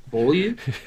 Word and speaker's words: Beaulieu: How do you say it? Beaulieu? – Is that what it is? – Yeah. – Beaulieu? – Yeah. Beaulieu. Beaulieu.